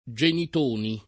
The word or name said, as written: Genitoni